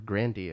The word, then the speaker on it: Grande: Unless she's trying to do a thing like Ariana Grande did with, or Grandi, as she pronounces it, or her grandpa pronounces it, with that other funny guy.